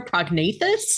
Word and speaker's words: prognathous: Without further ado, today's word of the day is prognathous, spelled P-R-O-G-N-A-T-H-O-U-S. Pronounced prognathous. It is an adjective that is defined as having a forward thrusting jaw.